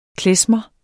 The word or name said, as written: KLEZMER